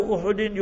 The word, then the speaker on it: Uhud: If there was a person who played a prominent role in the Battle of Uhud, Jabalu Uhudin yuhibbuna wa nuhibbuhu. Remember his hadith in Bukhari Sharif. Learn to pronounce the words properly. It's Uhud. Jabalu Uhudin with a dhamma on the ha, a pesh on the ha.